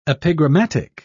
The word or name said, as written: epigrammatic